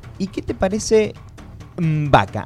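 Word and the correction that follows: M'Baku: Así como te lo pronuncio. M, el guioncito, el apócrifo. – Mbaku. – Mbaku.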